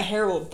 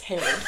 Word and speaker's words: Herod: It's not Harold Paul, it's Herod.